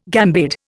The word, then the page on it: gambade: Indian